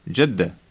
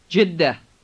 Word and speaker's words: Jeddah: You will find it easy to pronounce this name, but remember to double the D in the middle of the word. First, listen. – Jeddah.